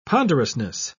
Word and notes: ponderousness: British English

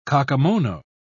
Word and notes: kakemono: British English

